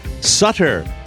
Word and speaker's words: Sutter: We'd like to say hi to all our listeners in Columbus, Ohio, Sutter Creek, California, Sutter. If you don't know, rhymes with butter.